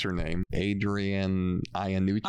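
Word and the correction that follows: Iapalucci: Iapolucci. – Iapolucci. Thank you so much. – Very funny. – Adrian Iapolucci.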